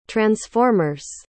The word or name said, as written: transformers